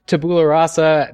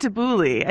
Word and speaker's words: Tabula Rasa: You say it like Tabuli. I think that's why you keep saying it that way.